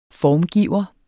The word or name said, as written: FORMGIVERE